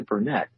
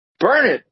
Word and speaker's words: Burnet: There's this town called Burnet, and it's spelled Burnett. And when you're from out of town, you say, "Well, how do I get the Burnett?" And they said, "Burn it, darn it, learn it."